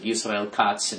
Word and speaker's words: Yisrael Katz: But Transportation Secretary Yisrael Katz, or Yisrael Katz, they pronounce it Yisrael Katz in Hebrew.